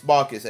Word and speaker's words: Markus: But yeah, it's Marcus, M-A-R-K-U-S. That sounds German. Believe Marcus. – Me, I know. – Marcus!